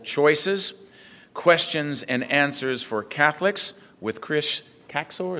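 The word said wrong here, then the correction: Kaczor: is that how you say it? – Kazor, thank you.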